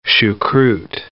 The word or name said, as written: choucroute